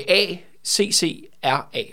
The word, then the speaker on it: Accra: Æ, øh, A, C, C, R, A.